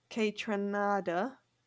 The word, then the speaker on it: Kaytranada: um, Noah Cyrus, D Smoke, Doja Cat, Kaytranada. Did I just say that right? Yeah.